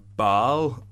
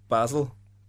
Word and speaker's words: Basel: And I just thought his passing was a little bit suspect against Baal. Basel.